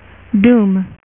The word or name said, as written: Doctor Doom